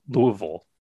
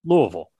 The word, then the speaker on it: Louisville: Uh, Friday, when I presume most of you will be listening to this, there will be two new Louisville Louisville tees. I'm trying to get the uh, regional pronunciation correct. Louisville,